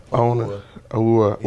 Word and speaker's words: Awuah: Awuah.